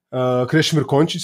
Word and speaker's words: Krešimir Končić: So I'm the agency owner and founder, Krešimir Končić, so you got the name right.